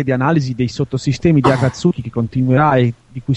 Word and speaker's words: Akatsuki: Akatsuki, no, è sulla prima sillaba.